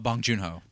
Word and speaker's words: Bong Joon-ho: and Joon-ho. Is that how you say it? – Bong Joon-ho.